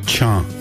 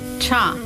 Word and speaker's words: Cha: In Dene Willy, how do you say rain? – Cha. – Cha. – Yes. Cha means rain.